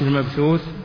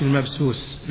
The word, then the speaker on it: المبثوث: فمثلا يقرأ كالفراش المبثوث فيقول كالفراش المبسوس بالسين، علما بأن هذا الرجل يحفظ أكثر القرآن ومن أهل الصلاح.